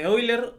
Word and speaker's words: Euler: este, yo creo que muchos han escuchado de Euler o también, bueno, se pronuncia Euler, pero se escribe Euler.